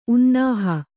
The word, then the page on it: ಉನ್ನಾಹ: unnāha